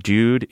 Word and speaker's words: dude: He says that he hears from a friend of his that dude is pronounced to rhyme with nude, N-U-D-E.